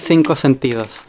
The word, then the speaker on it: Cinco Sentidos: Wie spricht man es aus? Ich hatte leider nicht Spanisch. Cinco. – Festival Cinco Sentidos.